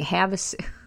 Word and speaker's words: Havasu: Havasu. Okay. I was like, what is he saying? Havasu. That's how you pronounce it.